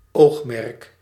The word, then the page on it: aim: /eɪm/; [eɪm]